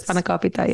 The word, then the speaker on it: spanakopita: Yeah, I'll be eating all of the spanakopita. How do you say it? – That was pretty good. Spanakopita.